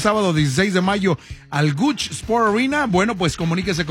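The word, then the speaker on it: Gucci: Ya me dijeron por acá que no se dice Gooch, es Gooch.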